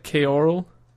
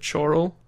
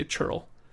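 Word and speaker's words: ceorl: It looks like Keorl, but you would pronounce it Chorl. – Mm-hmm. – Or, in today's parlance, we'd say Churl.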